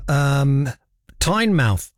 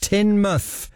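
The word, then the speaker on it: Teignmouth: Amy's just emailed and she says, "Hi, writing to you from Tynemouth." I'm saying it wrong deliberately. In Devon, as I shout at the screen, it's pronounced Tynmouth.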